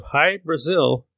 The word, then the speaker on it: Hy-Brasil: However, the lost island of High Brazil is just as intriguing and has more first-person accounts. High Brazil is spelled H-Y-B-R-A-S-I-L, and it's also spelled in many other variations, including Brazil spelled B-R-A-Z-I-L.